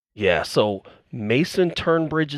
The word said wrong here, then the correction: Tunbridge: is back, or Tunbridge. I'm sorry, I keep saying his name wrong. Mason Tunbridge is back, baby, and we got Gio Vaccaro back too.